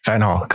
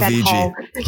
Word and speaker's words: Van Gaal: Uh, no, Van Gaal, come on. Van, LVG, Van Gaal, Louis Van Gaal.